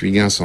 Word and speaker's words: Huygens: Huygens, euh, qu'on prononce Huygens en français, mais qui se prononce pas du tout comme ça, en hollandais bien entendu.